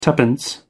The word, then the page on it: twopence: American English